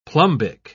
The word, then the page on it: plumbic: British English